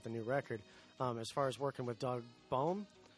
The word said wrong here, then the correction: Boehm: Bame. – Okay, I wasn't sure how to pronounce it. I don't think I've ever heard somebody say his name, so I wasn't sure. But I always said Boehm. – We're Kaler, and we're K-O-E-H-L-E-R.